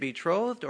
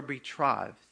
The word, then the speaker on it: betrothed: Well, how do you pronounce that? Is it betrothed or betrothed? So you know what the dictionary said? Both. Okay, just in case you're wondering. you can say either one.